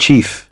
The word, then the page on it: chief: British English